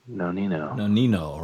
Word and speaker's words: Nonino: Nonino, right.